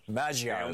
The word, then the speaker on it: Magyar: Yeah, Magyar is how it's pronounced.